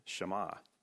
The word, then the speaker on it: Shema: It's transliterated, it's spelled Shema, but actually it's pronounced with the two short A sounds.